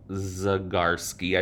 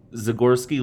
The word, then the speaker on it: Zagorski: Okay, so while Liz looks at that, we're gonna move on to Edmund Zagorski, I believe is how you say it, Z A G O R S K I, o r s k i.